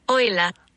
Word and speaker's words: Euler: That is not Euler. That's not Euler. That's Euler.